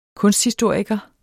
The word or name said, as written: kunsthistoriker